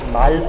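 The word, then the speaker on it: Mall: Er schreibt sich und spricht sich tatsächlich Mal aus, es ist nicht Englisch, also nicht Mal oder irgendwie, und Hülsmann.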